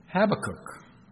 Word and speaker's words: Habakkuk: Now, I've only heard it ever once pronounced differently. I respect the lady. She's British. She's the wife of Stuart Briscoe, Jill Briscoe. She pronounces it Habakkuk. I'm going to stick with Habakkuk, but she could very well be right. That may be just how the British pronounce it.